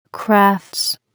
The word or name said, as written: crafts